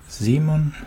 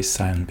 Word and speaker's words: Simon: This is Simon, as is the pronunciation in German, of course, of my name, but I rather like to say Simon because I don't know why, but people think it sounds cool, like, in Argentina.